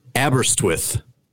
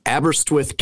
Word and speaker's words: Aberystwyth: Uh, I believe it is called Aberystwyth, Aberystwyth, Wales.